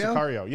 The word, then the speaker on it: Sicario: was he wrote Cesario. Cesario? How you spell it? Is that how you say it? – Sicario, yeah.